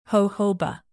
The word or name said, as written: jojoba